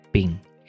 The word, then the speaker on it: PNG: portátiles, pronunciadas PIN.